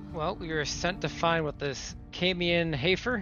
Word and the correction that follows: Amon Hayfoot: Am I saying that correctly? – Amon Hayfoot. – Amon Hayfoot. Sorry, I misheard you.